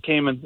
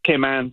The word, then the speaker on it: Cayman: I had it's not Cayman. It's Cayman, but anyways. – Is it really? Is it pronounced Cayman Islands? – Yes, Cayman Islands.